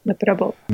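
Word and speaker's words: Nopperabō: Is that what I'm saying that right? – Yep. – Noperabu? – Noperabu.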